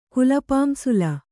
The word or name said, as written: ಕುಲಪಾಂಸುಲ